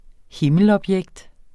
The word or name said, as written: himmelobjekt